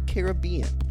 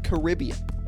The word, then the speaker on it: Caribbean: In England and the world of this movie, it's pronounced Caribbean. In America, it's usually pronounced Caribbean.